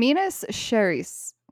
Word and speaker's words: Minas Gerais: In Minas Gerais.